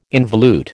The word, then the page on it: involute: American English